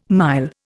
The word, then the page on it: mile: Indian